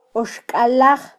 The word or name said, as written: oxkallaj